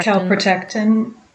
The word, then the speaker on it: Calprotectin: the The last checkup I had, which was now probably about three months ago, um, on the calpractin. What is it? Calprotein. Calpractin. – Calprotectin. – Calprotectin. I always forget how to pronounce it.